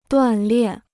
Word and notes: 锻炼: duàn liàn

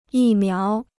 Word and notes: 疫苗: yì miáo

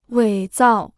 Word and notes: 伪造: wěi zào

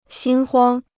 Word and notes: 心慌: xīn huāng

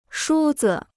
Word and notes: 梳子: shū zi

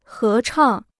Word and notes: 合唱: hé chàng

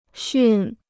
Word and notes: 驯: xùn

